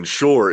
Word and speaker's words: Pschorr: But then Shore is Pschorr.